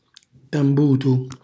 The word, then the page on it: tambutu: Calabrese